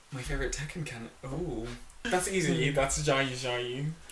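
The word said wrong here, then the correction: Xiaoyu: I think I'm saying her name wrong. – Zhao Yu, yeah.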